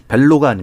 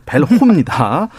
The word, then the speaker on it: 벨호: 발음을 잘해야 됩니다. 벨로가 아닙니다. 벨호입니다.